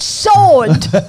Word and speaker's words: sword: No, it's sword. You got me saying it now.